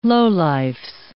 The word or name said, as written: lowlifes